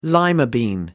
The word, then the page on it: lima bean: American English